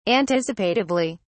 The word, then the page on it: anticipatively: British English